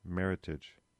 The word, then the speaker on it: Meritage: So it's not Meritage, which often gets used with that pronunciation. It's not a French term. It's entirely created here in the United States, and it's merit and heritage. Meritage.